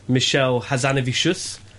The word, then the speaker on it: Michel Hazanavicius: But yeah, I've been out in the field, did an interview yesterday with the French Oscar-winning director Michel Hazanavicius.